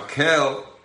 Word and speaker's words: Kel: Now, Kel means, obviously I'm saying Kel because we pronounce the K when we're in study.